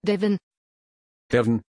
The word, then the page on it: devon: German